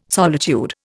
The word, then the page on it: solitude: Indian